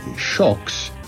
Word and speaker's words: Xocs: Shocks is spelled X-O-C-S in the English, but it is pronounced Shocks, and they were from Spain.